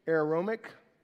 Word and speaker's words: aromatic: 250 of Aramaic," that's not how you say it, that's a different language, "aromatic." There you go, aromatic.